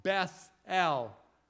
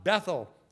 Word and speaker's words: Beth-El: And we pointed, I remember the night that Brother Caleb read that, and I said at that time, that's the way you pronounce it. It's not Bethel, it's Beth-El. El is God. Beth is the house of. This is the house of God.